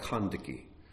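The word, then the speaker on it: Kandake: It's pronounced more like Kandiki, and it is the Ethiopian word for queen, the Nubian word for queen.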